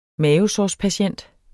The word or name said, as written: mavesårspatient